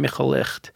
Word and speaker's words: Michal Licht: I've been translating the work of a very, very strange and important Yiddish modernist, Yiddish American modernist poet named Michal Licht in collaboration with Stephen Ross. – I didn't pronounce it well. – You did okay. – Michal Licht. – I've heard worse.